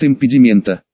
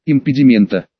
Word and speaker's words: импедимента: Вербальная формулировка звучит «импедимента». Ударение делайте на предпоследний слог. Еще раз, «импедимента». Отлично.